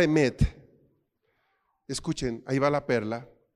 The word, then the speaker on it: emet: Hay una palabra en hebreo, emet, emet, se pronuncia emet no se escribe igual. Recuerde que las letras en hebreo son muy distintas. Emet se pronuncia así.